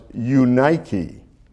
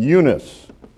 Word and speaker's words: Eunice: now it's actually pronounced Eunike, but I'm going to use the common English pronunciation, Eunice.